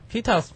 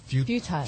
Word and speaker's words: futile: It's not pronounced that way, is it? – Futile. – Futile or futile. – It's futile, I think. – Tile? Is that a hard I there, the long I? – You can say futile or futile. Depends on your accent. Like, British is futile.